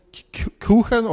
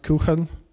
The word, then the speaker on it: Kuchen: So my name is Kuchen or Kuchen. I don't care how you pronounce it, but technically it's pronounced Kuchen. It's a German word, just spelled wrong because I'm funny.